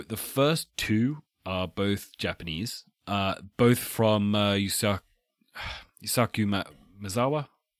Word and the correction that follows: Yusaku Maezawa: Sorry, I don't speak Japanese. You'll have to correctly pronounce that. – Yusaku Maezawa.